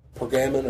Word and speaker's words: Pergamon: But we move on today to a church called Pergamon or Pergamum, however you want to pronounce that.